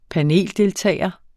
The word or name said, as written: paneldeltager